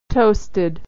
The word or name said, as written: toasted